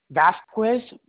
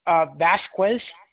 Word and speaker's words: Vazquez: Vasquez, V-A-Z-Q-U-E-Z. – Oh, wait. I'm sorry, ma'am. I saw your phone went out a bit. Can you spell that again for me? – Vasquez, V-A-Z-Q-U-E-Z. – And it's V-A-Z as in dog, zebra? – Yes. – Q-U-E-Z, another Z? – Yes.